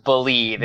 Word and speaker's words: BLeave: Totally spelled wrong, but it is pronounced BLeave, and BLeave. We BLeave in Tiger Woods.